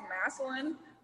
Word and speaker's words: Massillon: So Massillon, is that how you say it? Massillon? It's by Canton, right? So the NFL Hall of Fame. That's what pops into my head. – Mm-hmm. It's actually pronounced Massillon, but it's people mess it up all the time.